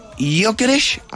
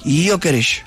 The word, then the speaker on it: Gyökeres: Las máximas figuras: Alexander Isak del Liverpool, lo mejor en la delantera, Víctor Gyökeres, así es que se dice exactamente. – ¿Cómo se dice? – Gyökeres. – Gyökeres. – Gyökeres.